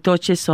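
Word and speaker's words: touché: I thought they pronounced it touche. – Touche, yes.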